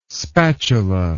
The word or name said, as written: spatula